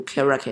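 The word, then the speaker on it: KRACK: 那我们再来验证一下，是不是读 crack？crack。 OK，那么读 crack，当然你读 crack 也是没有问题的。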